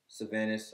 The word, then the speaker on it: Sahvannes: S-A-V-A-N-N. – Wait, you spelled your name wrong. – S-A-H. I used to do a lot of drugs. S-A-H-V-A-N-N-E-S.